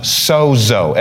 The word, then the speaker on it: sozo: The word saved is pronounced sozo, S-O-Z-O, in the Greek, which is what these were transcribed from.